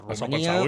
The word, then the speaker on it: Romania: Exacte, ho pronunciant bé, exacte, ho pronunciant bé, perquè com que ja sabeu que les nostres tòniques quan tornen àtones se pronuncien ús, a dir, no hi ha cap pega. Romania, Hongria.